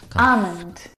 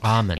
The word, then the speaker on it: almond: Almond. – Huh? – Now, in this word, the L is silent. – Almond. – It's not almond or almond. – Almond. – It's almond.